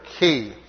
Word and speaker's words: chi: Uh, some of you who may know that is the letter chi. Uh, we're taught to pronounce it today the letter key.